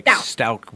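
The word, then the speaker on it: Stauch: Like Stouch with a K. Stouch.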